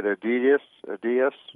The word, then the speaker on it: Adidas: And so many young people shop a company called, I think I'm pronouncing this right, Adidas. – Yeah.